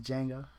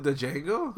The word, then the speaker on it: Django: Did he just call it Django? – Yeah, he pronounced the D. – DJ Ango? – D is silent.